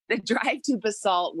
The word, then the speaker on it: Basalt: So then the drive to Basalt, and I thought it was called Basalt, and it's Basalt. And so then I was like, so when you guys, first of all, your city name rhymes with assault.